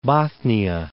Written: Bothnia, Gulf of

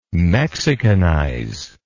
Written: mexicanize

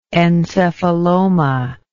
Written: encephaloma

